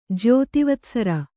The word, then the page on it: ಜ್ಯೋತಿವತ್ಸರ: kannada